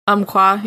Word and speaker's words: Umpqua: Umpqua, yeah.